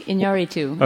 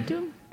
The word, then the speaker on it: Iñárritu: Did I butcher the name, or am I okay? – That works for me. Iñárritu. Okay. Iñárritu? – Good. Yeah, very good.